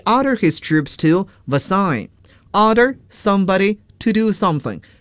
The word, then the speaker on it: Versailles: 我们再看这句话中的专有名词 Versailles，这是一个典型的法语词，我们要注意法语的发音 Versailles，凡尔赛宫。